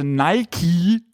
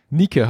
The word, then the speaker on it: Nike: Nike heißt die ja eigentlich. – Es ist aber tatsächlich so, also der Chairman von Nike hat tatsächlich mal gesagt, das ist die richtige Aussprache. – Also Nike.